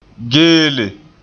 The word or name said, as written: geele